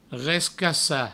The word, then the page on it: rescacar: rescassa; Provençal